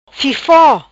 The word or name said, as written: Fifo